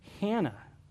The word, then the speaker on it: Anna: In English, we call her Anna, but the Greek text is pretty clear here. You actually pronounce it Hannah right from where we get the name Anna from.